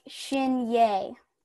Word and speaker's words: Shinyei: Okay, so question number one, how do you pronounce your last name? I have tried and tried it. I just don't know. So, You pronounce it like Shinyei. – Shinyei. Okay. My first thought was Shinyi, but I bet that's wrong.